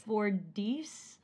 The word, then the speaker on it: Fordyce: How do you pronounce this? Fordice or Fordyce? – Fordyce, I think. I don't know. It's spelled F-O-R-D-Y-C-E.